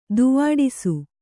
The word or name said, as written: ದಾವಡಸು